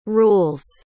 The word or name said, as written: rules